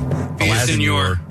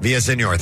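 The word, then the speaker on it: Villaseñor: Villasenor. – Villasenor, thank you.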